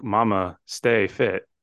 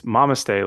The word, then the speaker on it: Mamastefit: And we should probably mention too, because I didn't know this, because I thought Mamastefit, like mama stay fit. It's Mamaste, like namaste, mama, S-T-E, fit.